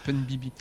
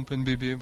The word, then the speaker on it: PunBB: Oui, c'est à la limite si on prononce en anglais. – Oui, ce serait Pun Bibi. Mais bon, Pun Bébé, bon, à la franglaise, c'est bien.